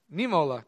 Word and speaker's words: Niemöller: How do I say this? Niemöller. Okay, thank you.